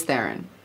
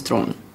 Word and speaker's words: Theron: I go by Charlize Theron, but it's the real pronunciation of my last name is Theron. Which is really hard for people to say.